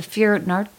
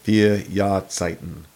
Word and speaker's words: Vier Jahreszeiten: How do you say that? The Vier Nord? What? – The Vier Jahreszeiten. – Wow. – Four season. Year. Zeit, like time. Zeiten. Jahreszeiten. Season. Jahreszeiten, time of year.